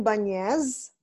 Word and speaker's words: Banez: It's Junaline Banez Musi, Banez. – right, with the Spanish accent on the end.